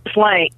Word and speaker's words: Ghislaine: Is it Ghislaine Maxwell? – Ghislaine.